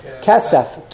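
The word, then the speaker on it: kesef (כסף): Silver is kesef, to a double segel.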